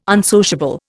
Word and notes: unsociable: Indian

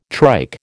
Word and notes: trike: American English

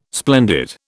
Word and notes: splendid: British English

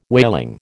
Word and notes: whaling: American English

